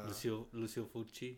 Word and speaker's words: Lucio Fulci: You know, Lucio Fulci came out in the 70s and 80s. I don't even know if I'm saying his name right. – Lucio Fulci.